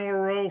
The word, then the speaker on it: Wawrow: It's Wawrow, right? – What rhymes with sorrow. – All right. John Wawrow from the Associated Press.